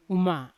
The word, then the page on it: uma: Yawa